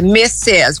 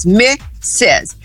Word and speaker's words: Mrs.: Mrs. é a mulher casada, Mrs. a pronúncia é Mrs. com som de Z no final, mas com duas sílabas, Mrs. Essa é a mulher casada, geralmente com o que usa o sobrenome do marido, tá?